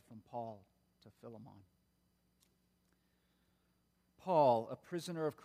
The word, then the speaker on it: Philemon: It is the book, the letter of Philemon, sometimes pronounced Philemon.